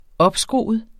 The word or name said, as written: opskruet